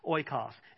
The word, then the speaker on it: oikos: And the Greek term for community or household in the New Testament is pronounced oikos.